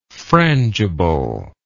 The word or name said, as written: frangibleness